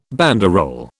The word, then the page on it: banderole: British English